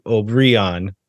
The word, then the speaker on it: Obrian: The one thing I'm not 100% clear on is where the accent goes on the last name. The MLS guide says Obrian and on the second syllable.